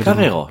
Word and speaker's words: Carrero: Carrero, dem, ja. Carrero. Ja. Ja, Carrero. Diesem, der hatte diesen gerollte R.